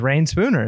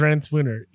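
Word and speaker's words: Reyn Spooner: I just assumed it was Rain Spooner. It's not Rain Spooner? No, it's Ren Spooner, yeah.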